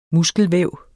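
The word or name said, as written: muskelvæv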